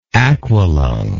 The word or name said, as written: aqualunger